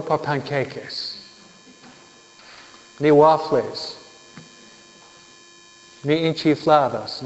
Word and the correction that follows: enchiladas: ¿Cómo se dice? – Enchiladas, ¿no? No enchifladas, ¿no? Enchiladas. Pero ustedes son chifladas por enchiladas, ¿no?